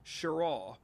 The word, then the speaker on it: Cheraw: It's just south of the North Carolina state line around what is known as Cherraw, and that's spelled C-H-E-R-A-W.